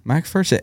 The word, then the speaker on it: MacPherson: MacPherson. And when you pointed out that like P-H-E-R next to each other is like gopher.